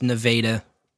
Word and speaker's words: Nevada: But there is a local Missouri town spelled Nevada, but is pronounced Nevada officially.